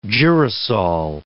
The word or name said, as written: girasole